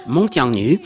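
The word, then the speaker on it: Meng Jiangnü: Alors, reprenez-moi parce que je suis à peu près sûr d'avoir mal prononcé Vincent Durand-Asté. Meng Zhongnu. – Alors, Meng Zhongnu.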